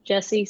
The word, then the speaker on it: Jesse: It's just my name, Jesse, spelled like a boy, I would say.